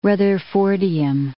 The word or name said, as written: rutherfordium